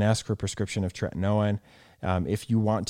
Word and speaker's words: tretinoin: What are your thoughts on retinol versus like a retinoid, like retin-A, or like what's the generic, the tretinoin? Am I saying that right? – Yeah, tretinoin.